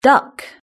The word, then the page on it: duck: British English